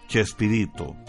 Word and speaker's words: Chespirito: De allí empezó a decirse que era como Shakespeareito, que se pronuncia Chespirito.